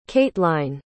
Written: cateline

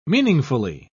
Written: meaningfully

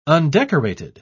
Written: undecorated

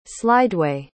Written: slideway

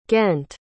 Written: ghent

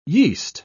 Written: yeast

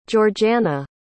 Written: georgeanna

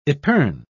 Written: epergne

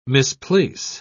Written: misplace

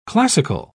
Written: classical

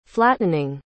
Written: flattening

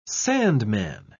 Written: sandman

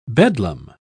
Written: bedlam

